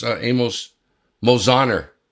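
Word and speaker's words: Amos Mosaner: Amos Mojaner. I pronounced those right, didn't I? – You did.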